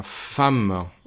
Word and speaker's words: femme: Mujer se pronuncia femme. Y no le busquen una explicación porque es femme.